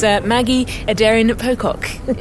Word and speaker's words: Aderin-Pocock: It's Maggie Aderin-Pocock.